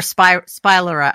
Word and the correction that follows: spiralizer: I always say it wrong. – Spiralizer. – Thank you. – Spiralizer. Yes. Yeah, I know.